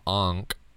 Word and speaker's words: ankh: So anyway, like ancient Egyptians and glands and chakras and expanding consciousness, and so the Egyptians had this symbol called the Ankh, and it's spelled A-N-K-H. I just spelt it O-N-K-H. It's A-N-K-H, and it looks like a Christian cross.